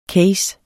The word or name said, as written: CASE